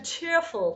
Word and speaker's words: cheerful: One last thing, actually going back to cheerful, which we talked about earlier, the last syllable of that, the full, is the open capital cookie sound, and not the schwa, right? – Technically, yes. The F-U-L suffix is most often realized, especially when it's drawn out, as flying you open, cheerful.